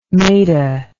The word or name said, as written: mehta